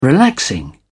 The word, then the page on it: relaxing: British English